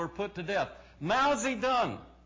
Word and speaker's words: Zedong: And that is how you say it, Zedong.